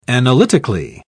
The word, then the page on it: analytically: British English